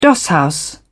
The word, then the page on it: dosshouse: British English